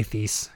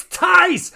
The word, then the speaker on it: Thies: In 1691, this 80-year-old man named Thies, maybe it's Tice, I don't know, T-H-I-E-S-S. It's probably Tice, but I'm going to say Thies because it's just the thought that maybe there's one of you out there that like, it's Tice! Ah, it's Tice! I'm going to keep saying it wrong just to annoy you.